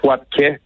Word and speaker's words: Wapke: En fait, on prononce Wapke comme okay, un accent grave.